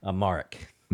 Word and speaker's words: Amharic: Amharic? – Amharic.